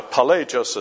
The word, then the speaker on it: Pelagius: Now, Pelagius is spelled P-E-L-A-G-I-U-S, Pelagius.